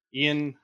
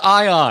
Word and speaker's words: Ion: To tell you more about our upcoming beta, I'd like to introduce our game director, Ian Hazzikostas.